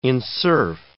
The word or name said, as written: enserf